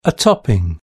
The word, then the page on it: a topping: British English